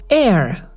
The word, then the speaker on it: Aire: Jetzt hat Kylie seinen Namen gepostet, er heißt Air, also wie Luft nur mit E am Ende, A-I-R-E.